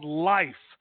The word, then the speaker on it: LIPH: The other product that I would recommend is a liquid product called Life. It's L-I-P-H. Now, again, it's a play on words, L-I-P-H, pronounced life, and that's where they put the P-H back in, life.